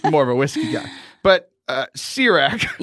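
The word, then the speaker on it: Ciroc: Sirach, I think it's made by P. Diddy or Puff Daddy. – Oh, that's C-I-R-O-C. – But that's exactly how you say it. It is a vodka. Sirach.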